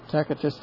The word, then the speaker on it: Tacitus: Tacitus the Roman, that's how you pronounce a C in Latin, is a hard C, K.